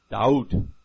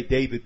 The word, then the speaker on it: David: We say David because we pronounce the Vav, V, rather than a W. Guess what? Amongst the ancient Hebrews, there were some that pronounced it with a W and some with a V. So, six of one, half a dozen of the other.